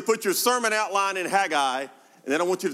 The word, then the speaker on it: Haggai: It's pronounced a million different ways depending on where you're from. If you're from the South, most people pronounce it Haggai, but I think the correct phonetical pronunciation is Haggai, but for those of us who understand classical country music, his friends called him the Hag.